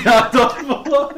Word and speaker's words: Adolfo: Wie spricht man diesen Namen aus? – Adolfo. – Adolfo. Punkt. Einfach nur Adolfo, der den Siegtreffer ja, in Turin sogar erzielte.